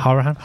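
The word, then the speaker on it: Hourihane: So how do you pronounce that, sorry? – Hourihan. – Hourihan, yeah. Oh, it's easy to fall into, isn't it? I've said Hourihan for 3 years now.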